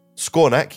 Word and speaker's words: Skawnak: Skawnak. yes. It's S K a W N A K.